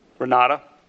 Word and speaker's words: Renate: You mentioned, I think, the Renate or Renate, Renata. I don't know how you pronounce that. That's a proper name of an individual you know? – Renata. – Renata. It's spelled with an E at the end, R-E-N-A-T-E.